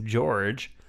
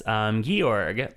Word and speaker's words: Georg: It's G-E-O-R-G, which I figured was just George. – Mm-mm. – But no, it's Georg.